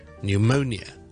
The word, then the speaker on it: pneumonia: 肺炎呢个字咧，pneumonia 咧，p n e u m o n i a， pneumonia。咁你發覺個串法裏面有個 P 字嘅，咁呢個 P 字我哋係唔讀嘅，所謂一個 silent P。咁啊，有網友就問我有乜情況之下會咁咧？